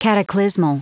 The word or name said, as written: cataclysmal